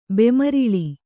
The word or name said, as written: ಬೆಮ್ಮಾರಲು